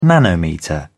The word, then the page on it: nanometre: British English